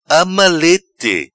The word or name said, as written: Ama leite